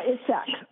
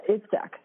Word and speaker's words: Itzhak: Yeah, Itzhak, or oh, just like a Z. Yeah, Itzhak. Yeah. Um, uh-huh. – Yeah, so it's like an STH or you know Itzhak Perlman.